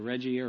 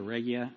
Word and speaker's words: Regia: And the entire world was run from the, what's called, I think I'm pronouncing this right, the Regia or Regia, R-E-G-I-A, temple, located in what is now ancient downtown Rome, Italy.